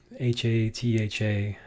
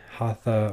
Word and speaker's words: hatha: And then hatha yoga, H A T H A, hatha or hatha is the traditional pronunciation.